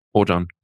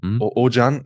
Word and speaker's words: Oajan: Ich weiß nicht, wie es ausgesprochen wird. Es wird geschrieben O-A-J-A-N. – Ojan. – Ojan, ja.